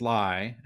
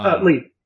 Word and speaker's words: Lie: We've got this pronunciation right, because I've heard Lai and Lee. – Lee. – Oh, okay. – I always called him Chris Lee. I knew him when I was in grad school. He was an undergraduate, and that's how he got involved with things. – At SCAD. – Yeah. – At Savannah College of Art and Design in Savannah, Georgia. Okay. – Yes. – So how did Chris Lee, which is spelled L-I-E, how did he come to Devil's Do, and then I'll do part two after.